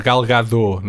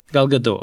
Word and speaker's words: Gadot: Acho que o T é mudo, Gal Gadot, não é? – Gal Gadot, ok.